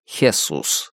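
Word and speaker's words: Jesús: Пусть даже оно и произносится как Хесус.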